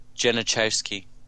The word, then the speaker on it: Genachowski: Julius Genaus. How would you say that last name? – Genachowski.